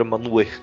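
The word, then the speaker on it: Manwë: O Manwe é, uma, é um valor estranho, porque se você falar ele exatamente como a pronúncia do Tolkien fala, manda, ele vira Manuel. Ele vira um Manuel assim, meio com um L, meio escondido no português, né?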